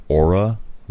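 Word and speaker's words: Oura: I would just like to state that it's pronounced aura, like A-U-R-A.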